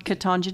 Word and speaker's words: Ketanji: Ketanji Jackson.